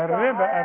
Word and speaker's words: الربا: الربا الربا. اسمعيني كلمه الربا. الربا والزكاه والصلاه تكتب بالواو وتنطق بالالف.